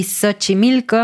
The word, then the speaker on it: Xochimilco: Y Xochimilco, aunque suena como S, se escribe con X, Xochimilco.